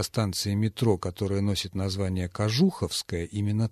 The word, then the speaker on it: Кожуховская: Или Кожуховская, мне казалось, или Кожуховская, а вот нет, оказывается, Кожуховская, именно так называется эта станция.